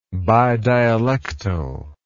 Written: bidialectally

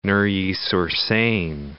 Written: Neuilly-sur-Seine

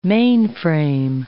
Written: mainframe

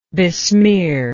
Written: besmearer

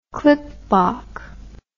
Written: klipbok

